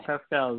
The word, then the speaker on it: Pascal: Pascal is right.